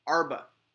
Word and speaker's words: Arba: How do you say it? – Huh, Arba. – Arba? – Yeah.